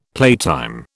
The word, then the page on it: playtime: British English